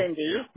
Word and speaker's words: Cindy: Cindy. – What's Sid? Can you spell that for me, please? – C-I-N-D-Y.